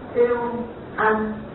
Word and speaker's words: Euangelion: Gốc từ của nó là tiếng Hy Lạp. Đọc là Euangelion. Còn nếu họ có cái tiếng copy.